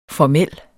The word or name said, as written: formel